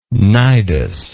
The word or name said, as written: cnidean